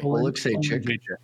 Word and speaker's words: Oleksiejczuk: I gotta say, how do you say his last name correctly? – Oleksychuk.